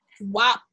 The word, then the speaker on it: WAP: Wop, wop, wop. So I think it's wop, but I really do think it should be pronounced as wap.